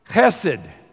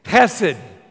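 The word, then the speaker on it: chesed (חֶסֶד): This word is a word pronounced chesed. Say it with me, chesed. I'll write it. You know, when you pronounce Hebrew, it sounds gross, but it's almost like a dog who's a little bit sick.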